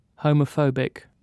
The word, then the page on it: homophobic: British English